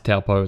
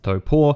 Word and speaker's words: Taupō: The most common one is Taupō, which is not actually pronounced Taupō, it's pronounced Taupō.